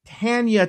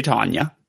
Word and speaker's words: Tanya: Yes, right, but most of us would say Tanya. – No, it's T-A-N-Y-A. – Well, I do agree with that. It actually is spelled Tanya, but I've never in my life heard anyone say that except Ms. Tucker. And that's it. – It's Oh, it's Tanya. It's Tan. It's not Tanya. It's not T-O-N.